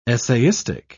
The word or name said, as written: essayistic